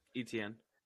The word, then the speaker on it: Etienne: I thought they were like the one-two punch, Terry Eaton. Uh, how you pronounce his name? – Etienne. Etienne. – Etienne. Okay, my bad.